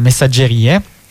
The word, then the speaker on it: Messaggerie: E io pensavo fosse francese, no? Io lo chiamavo messaggerie. Messaggerie. In realtà è italiano, no? La pronuncia è italiana, messaggerie.